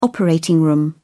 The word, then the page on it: operating room: British English